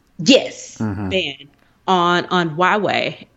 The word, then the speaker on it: Huawei: I can over pronounce this, Huawei.